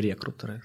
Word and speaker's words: рекрутеры: рекрутеры? – Рекрутеры. Вот, я... Э, правильное ударение. Э, рекрутеры. Рекрутеры. Вот.